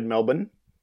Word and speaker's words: Melbourne: They have a farm outside Melbourne, which is a proper way of pronouncing Melbourne.